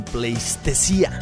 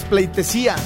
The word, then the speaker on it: pleitesía: Lo correcto es pleitesía, nada más que en la canción dicen pleistesía, está muy buena, pero con ese errorcito, con tremenda rola, se los perdonamos.